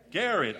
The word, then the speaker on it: Garrett: There's not much known about Goodloe's early years other than his connection to Jarrett County. – Garrett.